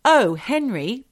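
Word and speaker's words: Oh Henry: It's said that Mary had been at the local dance with her boyfriend at the Oh, Henry Ballroom. I say it like that, Michelle, because it says, Oh, Henry. It's not like Oh, comma, Henry, like an Irish name. It's Oh, O-H, Henry Ballroom.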